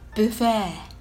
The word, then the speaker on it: buffet: スペルは B U F F-E-T です発音はブフェになり